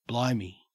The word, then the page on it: blimey: Australian English